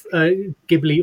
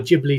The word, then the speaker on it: Ghibli: Well, there's a very famous animator called Mr. Miyazaki, who was for the head of Ghibli or Ghibli Studios, depending on how you pronounce it.